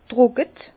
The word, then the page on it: trocke: Bas-Rhin; Herrlisheim